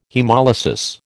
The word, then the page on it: haemolysis: American English